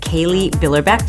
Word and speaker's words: Kailee Billerbeck: So I'm here today with Kaylee Billerbeck.